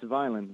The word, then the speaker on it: Vineland: And we're going to go then and talk to who's calling from Vinland, New Jersey. Did I say that right? – It's Vineland. – Vineland, okay.